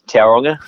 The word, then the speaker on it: Tauranga: And yeah, you got the pronunciation right there, Tauranga.